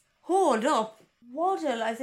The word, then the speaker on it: Waddell: As in waddle like a duck, Wallace. W-A-D-D-E-L-L, Waddle. – Waddle. – There is no other way I can pronounce it. I'm not giving it the Waddell treatment. It's Waddle.